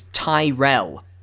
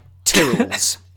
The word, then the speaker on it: Tyrell: I don't know if you picked up on it based on what I've been saying, but the correct way to pronounce their surname is Tyrell. – Okay. – Not the crisps, like Tyrrells. Sorry, for Americans. Potato chips, like Tyrrells.